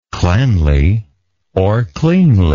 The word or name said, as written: cleanlily